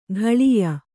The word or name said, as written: ಘಳಿಯ